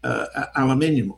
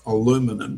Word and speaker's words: aluminium: The significant jump in aluminium or aluminum, depending on where we're going to be heard to pronounce this,